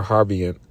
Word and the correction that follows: habergeon: Habergeon, with a band around about the hole that it should not rend.